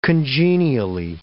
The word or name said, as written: congenially